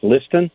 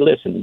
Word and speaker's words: Listen: My name is pronounced Liston, but it looks like Listen. So people say, man, you got a cool name, Jackie Listen, but it's actually Jackie Liston.